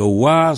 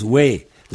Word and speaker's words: wa: Alors, je rappelle que wa se prononce we dans certaines régions.